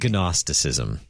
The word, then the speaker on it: Gnosticism: But it's spelled Gnosticism. – Yes. Gnosticism. It's from the Greek word gnosis.